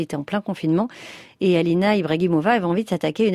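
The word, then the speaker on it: Ibragimova: Bien prononcé. – Vas-y, dites-le. – Alina Ibrahimova. – Oui, c'est bien dit.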